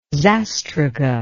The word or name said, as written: zastruga